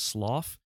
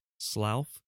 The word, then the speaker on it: Slough: Uh, the Bath Road Retail Park in I don't know how to say this Slough Slough S L O U G H. My my United Kingdom uh, listeners will tell me Slough.